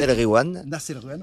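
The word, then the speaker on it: Nass El Ghiwane: Nas El Gwiam. Voilà. Et quand il y a GH, tu dis re. Tu fais comme si c'était un R.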